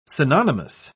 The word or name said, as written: synonymous